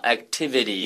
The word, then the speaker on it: activities: Oh, by the way, Jake, in regard to pronunciation, when you look at the word activities, mm-hmm. you see a lot of T's in there, don't you? – Mm. – Except the pronunciation is a little softer than that. I would not say activities. I would say activities.